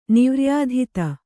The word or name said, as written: ನಿವ್ರ್ಯಾಧಿತ